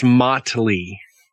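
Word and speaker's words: Mötley: Motley or Motley? – It's Motley. – Yes. – But isn't there like an umlaut or something over the O? Yeah, but it's a decorative umlaut, not a pronunciation umlaut.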